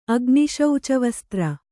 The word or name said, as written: ಅಗ್ನಿಶೌಚವಸ್ತ್ರ